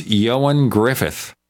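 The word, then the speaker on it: Ioan Gruffudd: And his name is pronounced Yoan Griffith. But if you look at the way it's spelled, and he spells it in the original Welsh, there's no way to figure that out.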